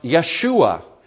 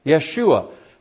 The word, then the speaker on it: Yeshua: In Hebrew, it would be pronounced Yeshua. Yeshua.